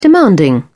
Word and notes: demanding: British English

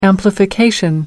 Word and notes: amplification: American English

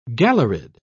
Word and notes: galleried: British English

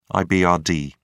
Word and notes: ibrd: British English

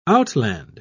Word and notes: outland: British English

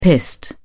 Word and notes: pissed: British English

